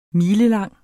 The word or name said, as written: milelang